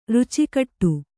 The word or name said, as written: ರುಚಿಕಟ್ಟು